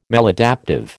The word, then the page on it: maladaptive: American English